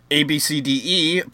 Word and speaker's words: Abcde: It says here, ABC affiliate KABC told Tracy Redford that several weeks ago, she and her daughter, ABCDE, pronounced Abcde. So, Abcde. Like, so you take AB and then the C, I guess you pronounce it as just a C, and then DE you pronounce as D, and you leave the E as silent?